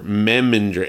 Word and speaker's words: Memminger: Anyway, so next up, we have playing Dean Menninger. Menninger? Is that how it was said? – Sure. – Menninger? – Memminger? – Where is the name so I'm saying it right? Dean Memminger. Memminger. M-E-M-M-I-N-G-E-R.